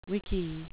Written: wiki

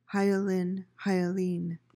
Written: hyaline

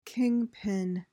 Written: kingpin

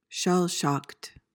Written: shell-shocked

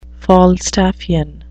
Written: falstaffian

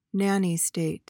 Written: nanny state

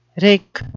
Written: wrick

